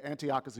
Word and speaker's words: Antiochus: Like, there was this guy by the name of Antiochus. Is that how I'm saying it right? Yeah, Antiochus Epiphanes, who tried to turn the Jewish faith into a Greek faith.